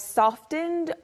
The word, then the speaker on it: soften: You usually soften the T sound in soften.